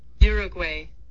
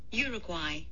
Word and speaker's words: Uruguay: So here's the U.S. version. – Okay. – Uruguay. – Oh. – Yeah. And here's the U.K. version. – Uruguay.